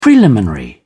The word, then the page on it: preliminary: British English